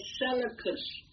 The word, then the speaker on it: Seleucus: It's spelled Seleucus. Somebody can pronounce that. The first Nicator. Leleucus. And I would say Seleucus.